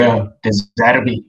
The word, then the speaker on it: De Zerbi: Um die mense wat vir my uitstaan is eintlik miskien bietjie die mense wat onder die radar vlieg en dit is uh Roberto De Zerbi en Oliver Glasner. – Um vir my is hulle wat wat De Zerbi ek hoop ek pronounce dit correctly. – Ja, yeah. ja, De Zerbi.